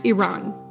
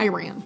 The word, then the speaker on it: Iran: Quick note before we get started, it's pronounced Iran. It's not a Flock of Seagulls song, y'all. It's not Iran.